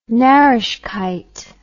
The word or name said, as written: narreshkeit